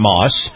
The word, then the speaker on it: Maus: And this portion of the program brought to you by our good friends at Moss Family Automotive. Moss is spelled M-A-U-S,